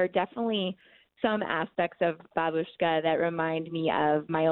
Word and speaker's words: Babushka: Now, one of your characters was just so endearing to me, and I'm going to say it wrong, but is it Babushka? – Yeah, Babushka.